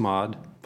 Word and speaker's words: Mod: And is it pronounced Mod, by the way? – Yeah, it's pronounced Mod.